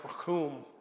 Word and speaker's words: rachum: It's the Hebrew word that's pronounced rachum.